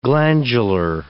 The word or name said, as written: glandular